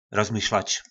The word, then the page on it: Rozmyšlač: Po našimu